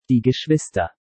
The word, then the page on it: Die Geschwister: German